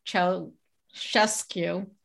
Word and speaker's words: Ceaușescu: It sounds dystopian, but this was the maniacal dynasty of dictator Nicolae Ceaușescu.